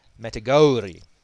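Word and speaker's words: Matagouri: You pronounced the word Matagauri. If you look at the spelling, the O-U should be pronounced Matagauri.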